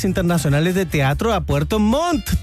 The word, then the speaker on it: Montt: Si eres de Puerto Montt o alrededores, no te puedes perder este hermoso panorama, los temporales internacionales de teatro de Puerto Montt. – Bien, es que hay que pronunciar las dos T.